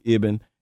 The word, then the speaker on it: Ibn: It's pronounced Ibn. It's an Islamic name. It means son of.